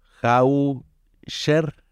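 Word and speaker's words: Haescher: Eh, un, Alsacia, de un productor que se llama Hosser, o Hau-Sher, así se escribe con H, H-A-E-S-C-H-E-R.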